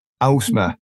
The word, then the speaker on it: Ausma: Ausma. – Ah, yes, very close. Well done. Yeah. It's Ausma. – Ausma.